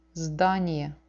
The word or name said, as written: zdanie